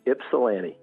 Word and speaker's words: Ypsilanti: Could you pronounce that town for me? – Absolutely. Absolutely. Ypsilanti.